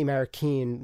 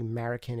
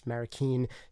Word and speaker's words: Marroquin: I'm going to be drawing a little bit on some interviews I did with a couple of great mixers, in particular, Manny Marroquin, one of my favorite mixers out there. Yes, that's how you say his name. It's not actually Marroquin, it's Marroquin.